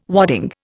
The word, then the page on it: wadding: Indian